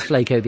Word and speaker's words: Flaco: Did you see anything like that with Flaco? If I pronounce it right, it is Flaco the owl. – No, actually, it's Flaco.